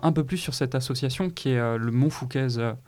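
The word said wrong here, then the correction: Montfauconnaise: je le dis bien ? – Non. Montfauconaise ? – Montfauconaise, ouais, pardon, désolé.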